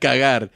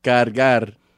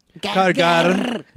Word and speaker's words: cargar: No, not cagar. – Oh. – Cargar. – Cargar. – Yeah. – Can you roll your R's? – Cargar.